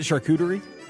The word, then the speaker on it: charcuterie: Is it charcuterie? – No, hang on, I'll get there. I'm glad you pronounced it right for once.